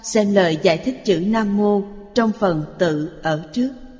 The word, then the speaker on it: Nam Mô: Chữ Nam Mô, thời cổ, hai chữ này được đọc là Nam Mô. Hiện thời, đọc thành Nam Vô. Chúng ta phải đọc theo âm thời cổ.